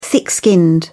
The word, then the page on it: thick-skinned: British English